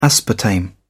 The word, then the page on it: aspartame: British English